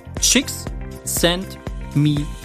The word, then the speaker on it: Csikszentmihalyi: Der Flow-Begriff geht auf Professor Mihai Csikszentmihalyi, ausgesprochen in etwa wie im Englischen Csikszentmihalyi, zurück.